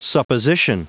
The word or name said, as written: supposition